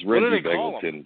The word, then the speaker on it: Begelton: If you guys really must know, I slept through most of the beginning of this podcast until Charles just butchered Reggie Begelton's name, okay? That woke me up. It's Reggie Begelton, just so you know, Charles.